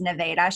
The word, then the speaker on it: Nevada: Yeah, Megan's going to be out there visiting a town which bears our name, but is not pronounced the same way, which is kind of funny because we're Nevada. Always correcting people from other places about Nevada. So in Iowa, it's Nevada.